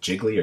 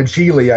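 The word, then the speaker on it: Gigli: was it Jiggly or Giggly, however the hell that movie is pronounced? – Yeah, Geely, I think.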